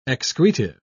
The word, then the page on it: excretive: British English